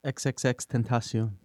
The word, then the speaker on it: XXXTentacion: Some people, and there's videos online that are talking about Extension, or how do you say his name? – XXXTentacion.